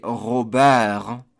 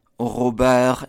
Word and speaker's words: Robert: For example, the name Robert, E-R-T in French, would be Robert, Robert, and you completely ignore that last T.